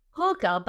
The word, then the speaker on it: hygge: Hygge, which is H-Y-G-G-E. Can you, Bill, help me out. Can you spell that into chat for me? It is pronounced who, H-O-O, ga, G-A-H. Now, when I went looking for how do you pronounce it, some of them said Hygge, like the O-O with the vowels, you know, the short vowel. And others said Hygge, which the long vowel.